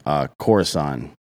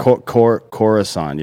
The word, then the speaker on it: Khorasan: Khorasan. – Okay. – Khorasan.